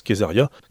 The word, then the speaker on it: Caesaria: Alors, ça s'écrit Caesaria, mais ça se prononce Kesaria.